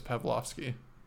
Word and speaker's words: Powlowski: Well, I said it rings a bell because his name is Pavlovsky. – Oh, it's spelled differently. – I don't care how it's spelled. – It's the Polish version.